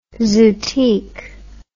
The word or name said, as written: zootique